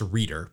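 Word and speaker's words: Reuter: I think it's pronounced Reader, but I'm going to go with Roto-Rooter.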